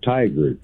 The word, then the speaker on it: Tigard: it almost sounds like tiger, but it's tiger. It's like T-I-G-A-R-D.